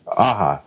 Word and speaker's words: Aja: You know, people were talking in Vertigo, but Image Comics tried is organizing, and then David Aja, Aja, wait, wait, Aha.